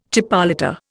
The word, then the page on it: chipolata: Indian